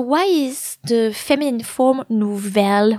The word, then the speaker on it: nouvelle: Nouveau, N-O-U-V-E-A-U, that's the masculine form of this adjective, and its feminine form is nouvelle. So it's like beau and belle. Actually, it works exactly the same way. So nouvelle is spelled N-O-U-V-E-L-L-E.